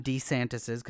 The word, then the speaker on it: DeSantis: it will end up on Ron DeSantis's, because apparently that's how you pronounce his last name, the moron.